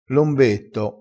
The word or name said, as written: lombetto